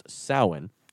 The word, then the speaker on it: Samhain: Way back in like ancient Celtic times, and this is like before the 8th century, there was a festival called Samhain, which is written Samhain, but pronounced Samhain, and we'll get into that a little bit later.